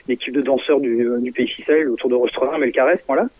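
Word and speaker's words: Fisel: Alors on ne dit pas Fizel, on dit Ficelle. – Ficelle, ouais, d'accord. – Ça se prononce Ficelle, c'est lié au pays Ficelle, petit pays de danse du centre-Bretagne. Voilà.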